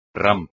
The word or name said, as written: rampa